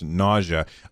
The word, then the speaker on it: Gnosia: The game is called Nausea, which is spelled G-N-O-S-I-A, but I assume it's pronounced Nausea.